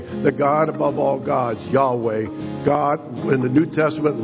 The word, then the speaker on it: Yahweh: He gives this name, Yahweh, it's pronounced in the Hebrew.